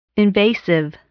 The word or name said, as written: invasive